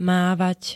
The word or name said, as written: mávať